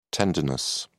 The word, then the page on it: tendinous: British English